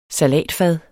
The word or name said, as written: SALATFADE